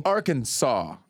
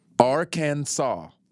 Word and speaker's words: Arkansas: The only acceptable pronunciation is in three syllable. Arkansas. – Okay. – With the final S silent. Well, shit. – Uh-oh. – Arkansas. Arkansas. – Sess. – No, you can't say the last S.